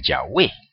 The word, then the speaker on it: Yahweh: Así que lo que usted tiene que hacer es simplemente colocar las vocales de la palabra Hashem en el tetragramatón y ya tiene ahí la pronunciación correcta del nombre del Padre, ¿verdad? Obviamente, esas dos vocales son A y E. Por lo tanto, entonces el nombre es Yahweh.